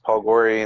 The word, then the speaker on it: Paul Gori: Facebook, they can just look up my name, just Paul Gori, and you'll see my...